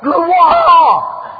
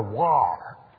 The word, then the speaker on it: gloire: Now my French professor would say, no, Mr. Scott, gloire! No mortal human being can pronounce this word except a Frenchman. The G, the L, and war. Not war, war. Gloire.